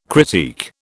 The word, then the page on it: critique: British English